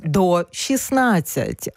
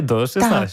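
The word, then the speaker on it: ДО16: І, власне, як вимовляти цю назву? Я вже знаю. До 16. А пишемо Д, О і цифрами 16. – Д, 0, 16, але читається до 16. – Так, так, до 16.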